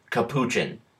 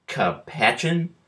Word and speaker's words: capuchin: Also, if it comes up, this podcast will properly pronounce the word capuchin, as in the adorable Central and South American monkey that neither Rob nor Livius were even close on. Capachin? Coop a chin? Come on, guys.